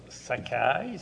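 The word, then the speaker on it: Székely: Zoltan, ça se prononce Sakai, ça s'écrit Zekeli.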